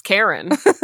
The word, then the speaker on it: Charon: C-H-A-R-O-N. – No. – How do you pronounce that? – It's Karen.